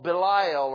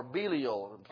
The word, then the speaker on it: Belial: He's called Belial or Belial, probably a better pronunciation.